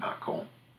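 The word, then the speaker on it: Mâcon: There's a town in Macon, France, for instance, M-A with a circumflex over it, C-O-N, Macon. And of course, you don't pronounce the N, you nasalize it, which is the source for Macon, Georgia.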